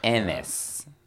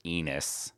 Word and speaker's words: Ennis: Ennis. I could say Enos, but I'm pretty sure. – There is a pause. – There's a space. – I don't think it's pronounced Enos.